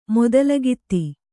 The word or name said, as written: ಮೊದಲಗಿತ್ತಿ